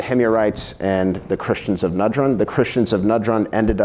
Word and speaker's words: Najran: Najran, thank you, sir. Najran.